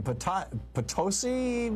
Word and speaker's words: Potosi: Potosi, Missouri. Am I saying that right? – That is correct.